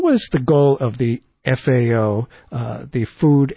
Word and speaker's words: FAO: So the Food and Agriculture Organization, which we pronounce the FAO, rhymes with now,